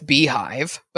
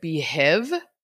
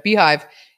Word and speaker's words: Beehiiv: It's, I'm guessing it's Beehive, but it's spelled B-E-E-H-I-I-V. So it looks like Behiv.